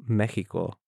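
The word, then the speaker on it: México: First of all, Texas in Spanish is pronounced Texas, just the same as in how Mexico in Spanish is pronounced Mexico, right?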